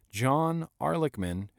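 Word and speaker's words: Ehrlichman: John Archleman, Archleman? A-H-R-L, Arlickman, Arlickman, Arlickman, John Arlickman, who was Richard Nixon's former chief advisor.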